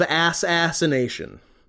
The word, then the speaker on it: Assassination: So these are the recent playthroughs on that channel of Hitman World of Assassination. Am nice. I pronouncing that correctly? – You are.